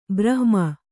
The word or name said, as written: ಬ್ರಾಹ್ಮಿ